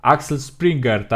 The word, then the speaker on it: Axel Springer: E, no, bardziej tak jak mówiłeś, ten koncern Axel Springer, tak?